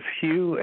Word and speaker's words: Hugh: My name is Hugh, H-U-G-H. The last name, Trawlson, is spelled T like Tom, R-A-U-L-S-E-N like Nancy.